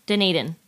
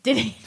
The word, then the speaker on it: Dunedin: Dunedin. – Dunedin.